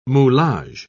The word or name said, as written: moulage